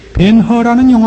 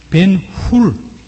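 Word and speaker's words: Hur: 벤허라는 영화가 있었습니다. 벤허, 그 영어 발음을 해서 H-U-R로 되어 그 허로 발음을 했습니다만 벤이란 이 히브리어의 자손이라는 뜻입니다. 벤훌, 훌의 자손, 유다입니다.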